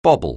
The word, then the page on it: bobble: anglais